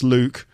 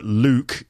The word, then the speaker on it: Luke: But again, that's probably because it's hard to pronounce Luke, or people don't realise that Luke, L-U-K-E, is actually a name, and they think that my name is Luck, which is quite cute.